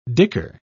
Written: dicker

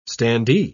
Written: standee

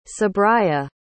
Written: sabriyya

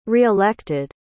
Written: reelected

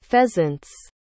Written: pheasants

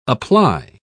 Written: apply

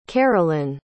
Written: karolyn

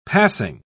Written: passing

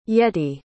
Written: yetty

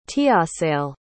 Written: tiassale

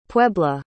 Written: puebla

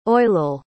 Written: oilell

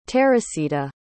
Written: terisita

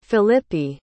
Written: Philippi

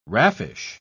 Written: raffish

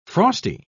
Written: frosty

